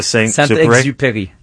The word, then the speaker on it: Saint-Exupéry: How would you say it? – Saint-Exupéry. That's correct.